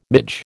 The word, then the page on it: midge: American English